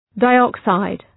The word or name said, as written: dioxide